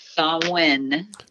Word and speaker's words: Samhain: How was your Samhain, if I'm pronouncing that right? Probably not. – Samhain. – Samhain. – Samhain. – Samhain. Okay.